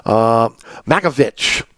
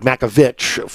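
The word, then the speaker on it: Matković: Makovic, okay, it was Makovic, now it's like Makovic from everybody that they're saying it's Croatian pronunciation.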